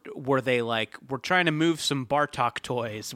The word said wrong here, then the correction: Batroc: Well, first off, I hate to correct a pronunciation, but it is Batroc. – I'm sorry. – I think this might give you a clue as to who wanted him in the book. – It's pronounced Batroc, but you might know it as Batcor.